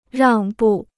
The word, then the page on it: 让步: ràng bù